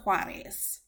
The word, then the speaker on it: Juarez: My very special guest today is our first international guest, Elsa Juarez, and I hope I pronounced that okay.